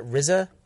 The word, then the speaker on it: RZA: Is that RZA? – Mm-hmm.